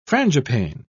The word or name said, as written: frangipane